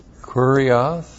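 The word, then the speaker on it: kurios (κύριος): Now, it is from this verb that we get a word that you all are familiar with, kurios. K-U-R-I-O-S, and that is the word translated Lord. That comes from the verb kurioō. And you know this very well because we have kurios, Jesus, Christos.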